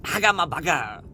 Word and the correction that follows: Nihongo ga hanasemasen: Nihongo ga hanasemasen. Und immer an die Aussprache denken. Immer als wenn du lächelst. Von einem Ohr zum anderen. Und alles zwei Oktaven höher.